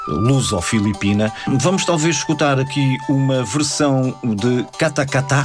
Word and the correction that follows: Katakataka: Sim, Katakataka. – Katakataka. – Isso. – Falta uma sílaba.